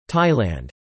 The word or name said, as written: Thailand